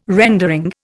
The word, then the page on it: rendering: Indian